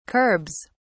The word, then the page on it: curbs: British English